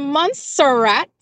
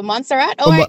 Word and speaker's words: Montserrat: Good morning from Barbados to Montserrat. How are you doing? – I am good. And at least you got the pronunciation right this morning. – What was that? For Montserrat? – Yes. Indeed.